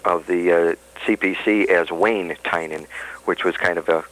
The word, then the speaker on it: Tynan: It's spelled T-Y-N-A-N, and it's pronounced Tynan.